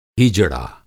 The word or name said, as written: हीजड़ा